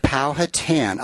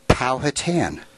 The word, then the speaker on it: Powhatan: He pronounces it, and I hope I'm doing this right, Powhatan. I hope that's right, Mike.